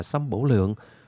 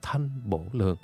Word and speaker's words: thanh bổ lượng: sâm bổ lượng. Đúng ra cái chữ nho phải đọc là thanh bổ lượng.